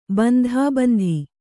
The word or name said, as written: ಬಂಧಾಬಂಧಿ